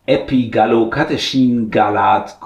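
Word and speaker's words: Epigallocatechingallat: Das eine Molekül aus dem grünen Tee, das wurde schon sehr beforscht und das ist Epigallocatechin-Galat kurz. Und darauf beschränken wir uns jetzt in dem Rest der Folge. Bitte EGCG, richtig? – Ja, ist ein fantastisches Molekül, also exzellent ausgesprochen auch von dir als Molekül-Dompteur.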